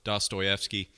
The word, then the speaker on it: Dostoevsky: it's called The Brothers Karamazov by I'm going to butcher the name. Fyodor Dostoevsky. – Yeah, um, you got it.